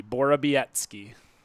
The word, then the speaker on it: Borowiecki: The W is pronounced like a B. Borowiecki.